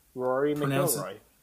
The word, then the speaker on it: McIlroy: Rory McIlroy.